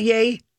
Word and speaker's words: Ye: Am I pronouncing it right by calling it Ye? – Yeah.